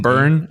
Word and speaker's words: Byrne: I don't know who Jamie Byrne, how do you say that? B-Y-R-N-E? – Byrne, I guess. – Byrne, probably, yeah.